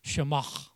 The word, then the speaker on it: Shema: Now, the way we'd probably pronounce it if we were Hebrews, we'd have to go, everyone do that. Come on, I'm going to, Shema. Come on, spit on the person's back, the back of the head in front of you.